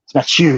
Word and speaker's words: Mathieu: And so when you say Matthew, it's Matthew.